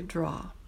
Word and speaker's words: draw: Draw, draw, the W here is silent. Just drop your jaw to say draw.